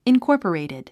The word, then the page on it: incorporated: inkɔ́ːrpərèitid; インコォポレィテッド